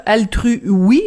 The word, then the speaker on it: AltruWe: A-L-T-R-U W-E, Altru Oui, c'est comme ça évidemment qu'il faut le, prononcer, un réseau euh, social altruiste très intéressant.